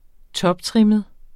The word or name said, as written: toptrimmet